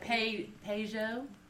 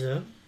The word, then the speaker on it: Peyo: Peugeot? – P-E-Y-O. – No, it's Joe. It's pronounced Joe.